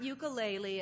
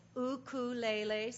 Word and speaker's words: ukulele: As I was studying this, Skip bought a ukulele recently. By the way, if you're going island, it's not ukulele, it's ukulele. Say it with me. Ukulele.